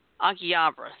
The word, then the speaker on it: Akihabara: When you return from Akihabara, am I pronounced that right? – Akihabara, thank you.